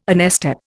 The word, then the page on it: onestep: Indian